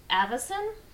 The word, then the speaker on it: Avison: After Simon and Kirby team moved to DC Comics in late 1941, having produced Captain America comics through issue number 10, Al Avison, is that how you pronounce it? A-V-I-S-O-N? – That sounds right.